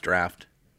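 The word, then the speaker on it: draught: And it's spelled D-R-U-G-H-T. Pronounced draft.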